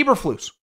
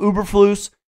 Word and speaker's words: Eberflus: No different than Eberflus. I used to call him Uberflus.